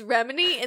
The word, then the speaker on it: Remini: Brie, you've been saying it, you literally, like, roll back the tape where, like, 5 minutes ago you were like, yeah, it's Remini, and then every time you said it, you said Rimini.